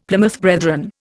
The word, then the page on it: Plymouth Brethren: Indian